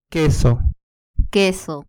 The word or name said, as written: queso